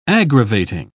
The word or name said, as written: aggravating